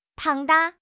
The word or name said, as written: 庞大